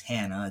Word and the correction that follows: Hanne: It's Hanne.